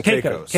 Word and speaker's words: Caicos: How do you say that? – Sure, Caicos. – Yeah. Caicos, yeah. Turks and Caicos.